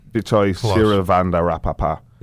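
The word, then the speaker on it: Vichai Srivaddhanaprabha: I will give it a go. Vichai Sirivandarapa. Vichai Sirivandarapa. – Yes.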